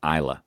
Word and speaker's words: Islay: Went to Macrahanish and went to the Isle of called Isla, which is spelled Islay.